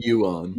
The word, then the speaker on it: Ewan: Is that how you say his name? Ewan? – Ewan. – Ewan, thanks.